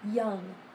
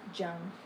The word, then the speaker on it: young: No, it sounds like this. Young, not jung.